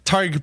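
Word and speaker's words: Tadhg: Tad Byrne, I think, has been incredible. For me... – Tyg Byrne. How often are you doing this with Shawnee and you haven't got your Irish pronunciations right? – Tyg Byrne.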